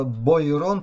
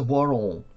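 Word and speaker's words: Boiron: Пишется Бойрон, читается Буарон по-французски. Такая фирма есть и в Америке, и так далее.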